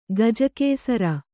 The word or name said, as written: ಗಜಕೇಸರ